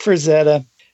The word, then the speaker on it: Frazetta: Frank Frazetta.